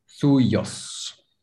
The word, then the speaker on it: suyos: Suos. Like the third one. Suos. – Suyos. – Suyos, but I said it. I just didn't know the pronunciation.